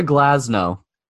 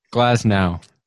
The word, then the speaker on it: Glasnow: Tyler Glasnow. – You didn't even say his name right. – Oh, man. – Glasnow.